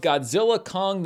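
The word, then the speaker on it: Godzilla x Kong: I'm here with my review of Godzilla Kong, the New Empire. Apparently, the X in the title is silent, which begs the question, why put it there to begin with?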